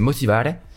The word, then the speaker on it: motivare: Donc je le dis à l'italienne. Donc c'est motivare.